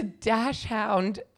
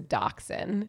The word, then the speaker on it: dachshund: That's what I think it is, but apparently it's a dachshund. – Oh God, we were both wrong. – No, yeah, it's spelled dash hound, but it's pronounced dachshund, which doesn't make any sense to me.